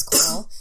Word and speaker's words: Coel: because my name is Coel. It's not spelt the conventional way, it's spelled C-O-E-L.